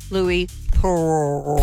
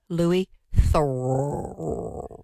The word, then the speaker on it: Louis Theroux: Would you like me to say his name correctly for you? – Yes, please. – Louis Theroux.